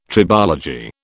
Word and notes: tribology: British English

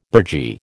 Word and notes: burgee: American English

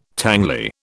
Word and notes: tangly: British English

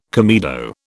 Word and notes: comedo: British English

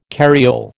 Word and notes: carriole: American English

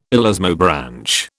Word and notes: elasmobranch: British English

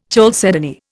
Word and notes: chalcedony: Indian

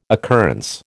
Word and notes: occurrence: American English

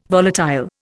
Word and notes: volatile: Indian

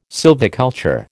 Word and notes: sylviculture: American English

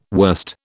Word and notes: worst: British English

